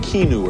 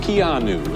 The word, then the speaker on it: Keanu: I was saying Keanu or something. It's Keanu.